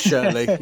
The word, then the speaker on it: Shirley: So now hopefully it's down in, I've got to get this right, Shirley in Solihull. I might say that wrong. – It is Shirley, yeah.